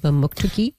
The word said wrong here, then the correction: Mamoktokati: No, Mamoktokati. – Mamoktokati.